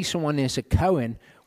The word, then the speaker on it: kohen: So priest in Hebrew is kohen. Okay, kohen is how you pronounce that.